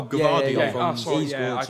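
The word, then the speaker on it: Gvardiol: Gavidol. Is it? – Oh, Gavardiol.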